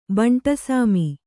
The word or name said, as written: ಬಂಟಸಾಮಿ